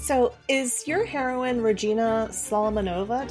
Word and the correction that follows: Solomonovna: Did I say that correctly? – Regina Solomonovna, and that's actually a patronymic because that's not her last name.